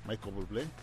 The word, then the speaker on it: Michael Bublé: Michael Bublé. ¿Eh? Sí, Michael Bublé. – Ok, mira, ¿cómo se dice? – Michel Bubul.